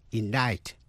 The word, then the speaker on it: indict: Và cuối cùng là C câm, tức là không phát âm, trong những chữ như indict, I-N-D-I-C-T, indict, indict, truy tố.